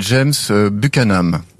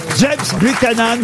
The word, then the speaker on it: James Buchanan: Alors c'est James Buchanan. – Bravo, et vous le prononcez bien, je crois. – Bravo. – James Buchanan, vous gagnez 100 euros.